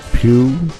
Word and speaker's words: Pugh: Ronnie Wood and Martin Pugh, that's how you pronounce it, playing all of the guitar parts.